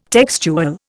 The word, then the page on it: textual: Indian